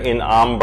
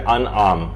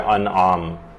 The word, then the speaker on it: انعام: اچھا، پہلے ایک چیز کی تھوڑی سی کریکشن کر دی جائے، وہ یہ ہے کہ لوگ اسے سورہ انعام پڑھتے ہیں، جبکہ یہ ہے سورہ انعام. یہ زیر کے ساتھ نہیں ہے بلکہ یہ زبر کے ساتھ ہے، تو اس کا صحیح تلفظ ہوگا سورہ انعام.